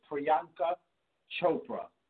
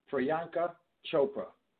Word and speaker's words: Priyanka Chopra: Her name is Priyanka Chopra. – You said it. You said it. Go start for Huggo. Say it again, Huggo, because I can't. Say it again. Pronounce it for me. – Priyanka Chopra.